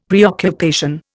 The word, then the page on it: preoccupation: Indian